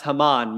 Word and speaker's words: Haman: A guy named Haman, or perhaps Haman might be a better way to pronounce it.